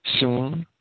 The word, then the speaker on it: synagoge: They're the three Greek words that give us the compound word, which we pronounce in English as synagogue, synagoge.